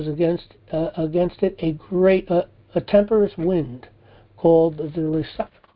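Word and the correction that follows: Euroclydon: Let me see how to pronounce this. – Euryclidon. – Euryclidon. Called Euryclidon. And when the ship was caught and could not bear up into the wind, we let her drive.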